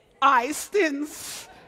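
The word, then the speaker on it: Oistins: Is it Oyskins? Ah? Oystins. I didn't say it right.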